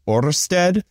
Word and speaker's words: Ørsted: Ørsted.